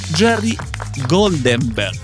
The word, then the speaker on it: Jerry Goldenberg: En el 2016 fue galardonado como el mejor profesional digital de Argentina en los premios Jerry Goldenberg. ¿Lo pronuncié bien? – Perfecto.